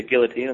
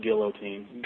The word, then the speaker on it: guillotine: Benson Smooth Henderson did keep his lightweight title in the co-main event, defeating Donald Cerrone via guillotine in 2 minutes of the first round. – It's pronounced guillotine. – Guillotine, you're right.